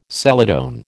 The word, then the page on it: celadon: American English